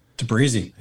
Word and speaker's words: Tabrizi: Tabrizi.